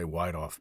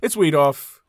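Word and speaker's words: Wiethoff: I probably would have pronounced it wrong, too. Wheatoff, or some people, a lot of people say Wheatoff, poor guy. – What is it? – It's Wheatoff. – It's Wheatoff. – Rob Wheatoff, yeah.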